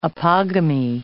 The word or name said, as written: apogamy